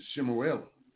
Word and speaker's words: Shemuel: I know some of you think it's Samuel. Bastardized name, Samuel. Nobody's pronunciation is Shemuel, first chapter 20.